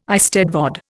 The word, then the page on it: eisteddfod: Indian